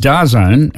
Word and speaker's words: DAZN: It's pronounced DAZN, and it's slowly changing the name.